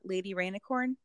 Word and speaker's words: Lady Rainicorn: He wants to hang out with Finn, but he also wants to hang out with his girlfriend, who is Lady Rainicorn.